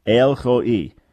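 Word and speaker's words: El Roi: So you can spell it if you like, R-O, and then a apostrophe, like a quote sign, and then I, or E-E, if that helps you understand it, Ho'i, El Ho'i.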